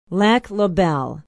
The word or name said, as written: lac la belle